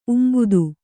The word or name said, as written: ಉಂಬುದು